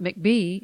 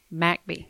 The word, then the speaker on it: McBee: It's not McBee, it's MacBee.